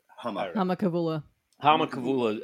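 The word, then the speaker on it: Humma Kavula: But, so anyway, as they're leaving, Harmon Kavula, is that how I pronounce it? – Hama Kavula.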